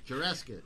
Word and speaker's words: Jurassic: Así no se dice otra vez, güey, con lo mismo. Otra vez. – No, pues Jurassic Park. Jurassic. Muy bien.